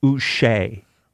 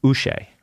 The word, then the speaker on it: Uche: Now, we've been saying it wrong. I looked up the pronunciation. It is Josh Uche. – Uche? – I don't like Uche? That. It's Uche.